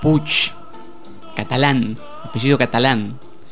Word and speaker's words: Puig: Escuchábamos recién la voz de Manuel Puig. O Puig o Puch. ¿Cómo se dice, Anabela? – ¿Cuántas variantes? No sé, según el gallego de a fondo se dice Puch.